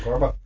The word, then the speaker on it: CORBA: And like, I remember like the first meeting this lady, it was, how do you pronounce that old middleware between C and Java? It was a Corba project. Corba, is that it? – Corba. – Yeah, I was doing Corba, which is like this middleware standard for exchanging information between like C APIs and Java or whatever.